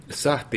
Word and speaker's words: Sahti: That's how you say it, Sahti? – Sahti is the right name.